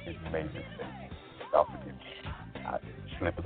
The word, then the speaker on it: Mavis Staples: Let me correct myself. I knew I was saying that woman's name wrong. Her name is Mavis Staples.